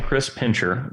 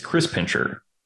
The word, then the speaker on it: Pincher: Yeah, you're saying Chris Pincher, which I understand. It makes sense because that's how an American would say it. It's Chris Pincher. It's like someone who pinches Chris's.